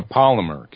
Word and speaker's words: polymer: He's been out here for a number of years as a Palomar, and please forgive me if I mispronounce that, as a Palomar chemist. – A polymer chemist.